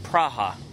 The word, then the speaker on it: Praha: And actually, my ex-girlfriend used to live in Prague, which she pronounces it Praha.